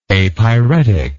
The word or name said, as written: apyretic